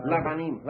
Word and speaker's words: Lavanim: Is it Lavanim? Levanim? – Lavanim.